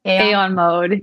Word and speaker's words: Aeon Mode: It's kind of like how you guys have like your main stage and then you'll have like blank with, how do you pronounce it? Aeon Mode? – Aeon Mode. – Yeah. Aeon Mode.